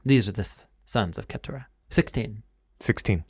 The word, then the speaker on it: Keturah: Her name was Keturah. It rhymes with the Hebrew word for crown, spelled differently, but it rhymes with crown.